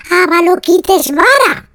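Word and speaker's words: Avalokiteshvara: Avalotiquesvara. Ah, no, lo he dicho mal. Avaloquitesvara.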